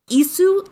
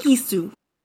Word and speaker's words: qisu: Now, Isu is, I'm sure I'm not pronouncing it right, but it's a non-vocalized Q. It's spelled Q-I-S-U. You don't pronounce that Q, you make it a glottal stop instead.